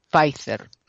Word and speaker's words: Pfizer: De hecho, los medios españoles, están tratando de pronunciarlo como se pronuncia oficialmente. Es una empresa norteamericana, una gran farmacéutica, una gran multinacional estadounidense, y lo pronuncian Pfizer.